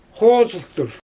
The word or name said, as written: xoozɯltur